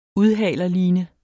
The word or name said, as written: udhalerline